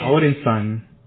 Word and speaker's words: Odinson: Yeah, Odinson.